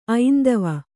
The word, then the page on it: ಐಂದವ: aindava